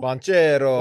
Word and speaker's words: Banchero: Were you referencing Paolo Banchero? – Banchero. – What did I say? – It's not... – Is he Italian? – I say Pancho Bailero. – You said whatever, you absolutely butchered it. I think you said Pancho and Lefty.